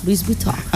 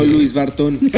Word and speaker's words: Louis Vuitton: Ah, es que la nombraste diferente. – Ay, bueno, es que yo no sé pronunciar otros idiomas. Yo lo digo a lo colombiano, a lo chichombiano. – No, y está bueno. bien. – Antes dejó Luis Bartón.